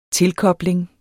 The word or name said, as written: tilkobling